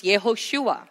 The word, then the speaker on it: Yehoshua: And Joshua is anglicized, right? So in original pronunciations, Yehoshua. It's the same name as Yeshua, which means to save his people from their sins.